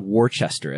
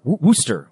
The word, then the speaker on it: Worcester: It's not Worcester as it appears. It's Worcester. Worcester, Massachusetts.